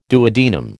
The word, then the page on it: duodenum: American English